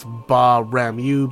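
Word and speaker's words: Bah Ram You: And if you have a chance to go check out their SoundCloud, again, that's Ba-Ram U, B-A-H space R-A-M space Y-O-U. Think the sheep from Babe, except U is spelled as in you there.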